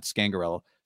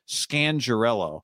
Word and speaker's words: Scangarello: I found out we've been saying it wrong this whole time, not Scangarello. Scangarello.